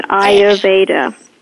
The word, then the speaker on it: Ayurveda: So, but yeah, you said it right, and I'm glad you did that because a lot of people don't know how to say it. It's Ayurveda.